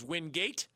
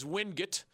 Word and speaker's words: Wingate: Clearly, he has Lenoir Rhine at 9-0 after the win over previously unbeaten Wingate, which I've been told can be pronounced as Wingate or as Wingate. Proud products of that program say that it is a debate even among them.